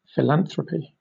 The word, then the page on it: philanthropy: British English